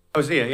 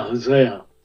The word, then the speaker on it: Hosea: So these same people who are so rageful, O.C. would be an outstanding example. I think you pronounced it a little different. How do you say O.C. over there? Hosea? – Hosea, yeah. – Yeah, Hosea. I mean, his language is almost, it isn't almost, it's erotic.